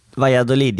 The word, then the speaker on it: Valladolid: Den, Uttalar jag rätt eller? – Valladolid ja, helt korrekt.